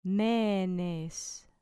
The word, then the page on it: menes: grec